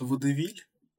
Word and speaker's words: Водевиль: Правильно я произнес? Э, по-моему, правильно читается «Водевиль».